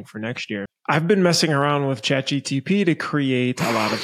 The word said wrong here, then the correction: ChatGPT: charts, which is pretty crazy because from a reporting standpoint, hasn't figured out how to pronounce it yet. – ChatGPT.